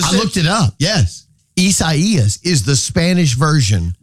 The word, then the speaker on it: Isaias: The emphasis is on the second E. Isaias.